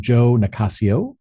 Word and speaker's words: Nicassio: Please help me welcome Joe Nicassio. Contrary to... – Thank you. – You startled me there for a second. Okay. I was pronouncing it correctly. – Yes. Okay. Nicassio.